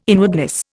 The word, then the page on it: inwardness: Indian